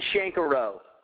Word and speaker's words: Shenkarow: Did I say that right? Holy crap. – Shenkarow. – How do I say it? Do it again. – It's Justin Shenkarow. – Shenkarow. – That's it.